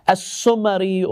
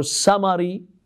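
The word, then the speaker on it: Samarri: Now, when Al-Husayn ibn Ruh passes away, the last deputy, the fourth one, is Abu al-Hasan Ali ibn Muhammad as-Sumari or Samari. There are different pronunciations. There's a third and fourth pronunciation. Let's say as-Samari or as-Samari. As-Sumari is also a common pronunciation.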